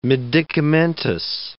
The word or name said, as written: medicamentous